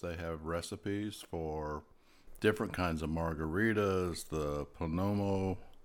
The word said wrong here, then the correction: Paloma: Is that how you pronounce it, Joe? – The Paloma.